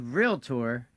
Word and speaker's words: realtor: How do you say that word? – Realtor. – It's not realtor? – If you say realtor, you're probably not a realtor. – I know a lot of realtors that say realtor, like the guy on the show right before us, by the way.